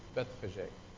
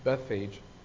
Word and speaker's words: Bethphage: they entered into a city called Bethphage," sometimes pronounced Bethphage, but Bethphage.